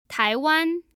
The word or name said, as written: taiwan